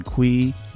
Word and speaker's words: Cui: And of course, you could follow me, Brian Kui, last name is spelled C-U-I, on LinkedIn.